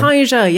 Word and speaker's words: Wakanija: And again, to what Josie said, and the same idea of the word Wakanija, am I saying it right? – Awanglake. – No, I'm talking about for the children. I'm sorry. – Wakanija, yes.